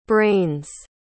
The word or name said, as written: brains